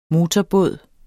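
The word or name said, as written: motorbåde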